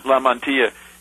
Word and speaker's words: Lamantia: So, it seems that Phil Lamont, how do you say his name? Phil Lamantia? – Lamantia.